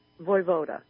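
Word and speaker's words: Vojvoda: Voivoda. – Voivoda. – Voivoda. Huh? – We weren't sure what to do with the J.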